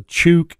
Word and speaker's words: Chuuk: Yeah, it's a Chuuk is the name of the native name, Chuuk, which pronounced Chuck or Truck. – Well, I'm sure the Americans pronounced it Truck based on what they were hearing, And but the warships got it.